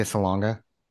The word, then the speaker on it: Salonga: How do you say Nope. – her last name? Am I saying it wrong? – Leah Salonga. – Leah Salonga. Okay. I think I'm switching the G and the N. Salonga, like I'm saying baloney or Bologna or whatever.